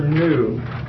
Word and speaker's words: nu: Number 13 is the letter nu, which rhymes with mu, but they're different letters.